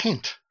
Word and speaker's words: Ghent: Ghent, I think it's pronounced. G-H-E-N-T in Belgium.